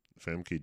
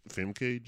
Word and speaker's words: Famke: Famke Janssen. F- Fact me Jensen. That's is this right? – Famke Janssen.